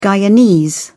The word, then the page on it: Guyanese: British English